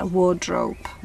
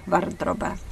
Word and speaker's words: wardrobe: že šatní skříň se řekne wardrobe. Varde Robe. Můžeme si to pamatovat, že Robe jako roba, Varde wardrobe, wardrobe. Jo, se to píše, čte se to ještě jednou wardrobe.